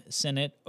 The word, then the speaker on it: Sinnott: And Senate is spelled S-I-N-N-O-T-T, so it's not like the Senate. You know, that legislative body that we all have so much confidence in. It's somebody's last name spelled completely differently.